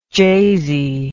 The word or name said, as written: jasey